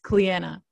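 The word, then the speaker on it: Clíodhna: This is spelled Cleodhna and is pronounced Cleanna.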